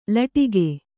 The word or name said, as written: ಲಟಿಕಿ